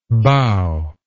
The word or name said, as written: boughy